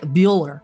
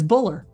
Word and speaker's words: Bueller: Like Bueller. Picture. Yeah, exactly that. Picture how that's spelled. It's B-U-E-L-L-E-R.